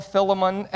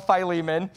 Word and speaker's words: Philemon: In this tiny letter that some call Philemon and some call Philemon, Paul is writing to a dear brother named Philemon or Philemon. It's pronounced both ways.